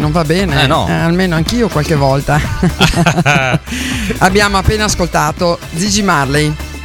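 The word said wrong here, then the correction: Ziggy: No, attenzione, no, Gigi. – Zigi. – Zigi. – Perché io ho la pronuncia della Z, che è un po' particolare. Sì, Zigi.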